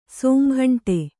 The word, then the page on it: ಸೋಮಘಂಟೆ: kannada